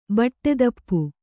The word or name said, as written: ಬಟ್ಟೆದಪ್ಪು